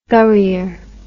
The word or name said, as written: gurrier